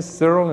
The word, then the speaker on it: Cyril: After all, my name is Cyril and it rhymes with squirrel, and I store things up.